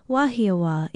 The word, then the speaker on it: Wahiawa: Wahiawa, our Hawaiian word for today, is one of those beautiful Oahu place names that is so often mispronounced. It is spelled W-A-H-I-A-W-A with a kahako or stress mark over the last vowel. Wahiawa, it means place of noise, for the rough seas could be heard all the way to Wahiawa.